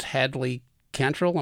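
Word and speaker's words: Hadley Cantril: His name is Hadley Cantrell. Am I pronouncing that right? – Yep.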